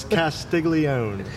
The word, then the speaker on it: Castiglione: It's Castiglione.